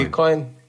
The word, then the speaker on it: Dogecoin: Prvo, uh, Dogecoin oziroma Dogecoin, ne vem točno, kako se izgovori. Uh, Dogecoin ali pa Dogecoin. Ja, Dogecoin.